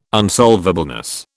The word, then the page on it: unsolvableness: British English